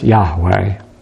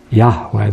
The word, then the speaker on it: Yahweh: But we are reasonably sure, and I'm probably more sure than most, it was pronounced Yahweh. Yahweh.